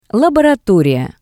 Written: laboratoriya

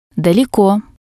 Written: daleko

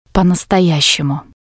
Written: po-nastoyashemu

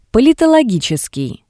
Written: Politologitseskii

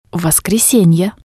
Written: voskresenie

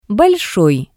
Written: Bolshoi

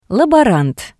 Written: laborant

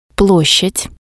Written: ploshad

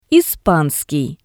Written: ispanskii

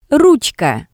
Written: rutska

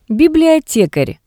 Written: Bibliotekar

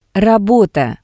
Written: rabota

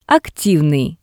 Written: activnii

